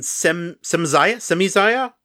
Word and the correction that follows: Semyaza: I should not pronounce that. – I think it's Semyaza.